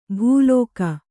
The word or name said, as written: ಭೂಲೋಕ